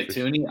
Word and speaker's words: Thuney: I think I butchered his name. – It's Joe Thuney. – Yeah, Thuney.